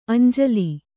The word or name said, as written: ಅಂಜುಲಿ